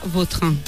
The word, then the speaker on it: Vautrin: Es que a mí me suena mejor Vautrin. Es como... – Da más fuerza, ¿no? – Más fuerte, sí, más la pronunciación. – Sí. Elegante. – La original francesa, Vautrin.